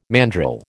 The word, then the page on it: mandrill: American English